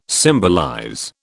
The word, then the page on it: symbolize: British English